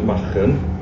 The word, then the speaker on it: Machen: Well, the place in Wales, I call it Machen. – Yeah. – Because I speak Welsh.